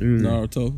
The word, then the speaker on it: Naruto: Naruto. Is that how you say it? – Mm-hmm. Yes, sir. Naruto.